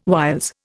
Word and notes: whiles: Indian

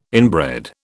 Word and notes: inbred: British English

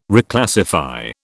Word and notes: Reclassify: British English

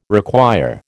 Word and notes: require: American English

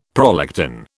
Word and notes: prolactin: British English

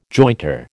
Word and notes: jointer: American English

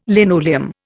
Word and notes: linoleum: Indian

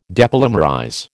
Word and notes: depolymerize: American English

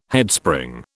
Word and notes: Headspring: British English

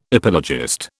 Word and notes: epilogist: British English

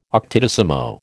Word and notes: octodecimo: American English

